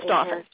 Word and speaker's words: Dauphin: Am I pronouncing that right? – Dauphin County, yes. I think it's Dauphin.